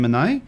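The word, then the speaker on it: Anaïs: Oh, that's a pretty name, Anae. A double N. How do we spell that? – A-N-A-I-S. – Yes, that's what I thought. Right. Anae, your number this morning is...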